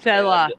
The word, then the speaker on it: Selah: Or Selah, I hope I'm pronouncing that correctly. – Selah, yeah.